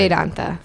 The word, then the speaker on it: Vedanta: Am I going to pronounce this right? – Vedanta. – Vedanta. Vedanta? – Yeah. Okay. That was good.